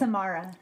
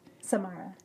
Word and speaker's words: Samara: Samara. – Tamara's? – Samara, yeah.